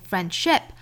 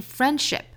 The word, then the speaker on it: friendship: 好，那我们再看后面 That's not how friendship works，两个 not 都是发 not， not， That's not how friendship friendship works， friendship 后面这个 ship 不要发成了 sheep，应该是口型稍松的 i 的发音 ship ship。那这个词的重音呢是在前面这个音节，所以不要读成 friendship，应该是 friendship， friendship works， friendship works。